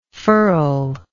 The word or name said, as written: furler